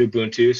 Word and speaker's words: Ubuntu: Yeah, and I pronounce it Ubuntu, so the people you talk to, you might hear something different.